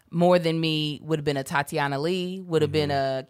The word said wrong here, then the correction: Ali: Tatiana Ali.